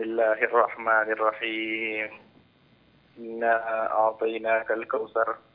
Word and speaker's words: الكوثر: لا لا لا، في حرف الثاء، قل مثلي بارك الله فيك، قل حرف الثاء، انطق الثاء، الكوثر. – نعم. الكوثر، ايوه هكذا، ايوه تمام، لا تقول الكوثر، خليها الكوثر دائما.